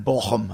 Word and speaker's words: Bochum: and then Bochum, I know Bochum is right.